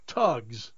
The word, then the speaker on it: Thugs: They are pronounced tugs, but they're spelled thugs.